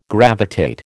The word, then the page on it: gravitate: American English